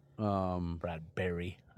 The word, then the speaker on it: Brad Berry: That'll make Brad Perry at North Dakota happy. Brad Berry, I should say.